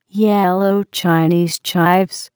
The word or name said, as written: yellow Chinese chives